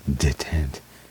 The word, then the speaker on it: détente: However, Constructed in 1980s, the Cold War began heating up again following détente. How do you pronounce that word? D-E-T-E-N-T-E, the French word.